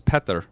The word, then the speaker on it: Petar: So my name is Petr Spajic, but Peter Spajic is my name in America. And so through the years, I would just be like, I would say my name Americanized, right?